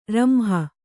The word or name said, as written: ರಂಹ